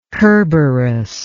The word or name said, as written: kerberos